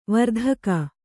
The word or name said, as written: ವಾರ್ತಾಕ